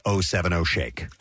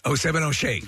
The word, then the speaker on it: 070 Shake: By the way, it's been confirmed that Lily Rose Depp's significant other now, his name is pronounced 070 Shake. – 070 Shake. – There you go. So got the definitive word on that.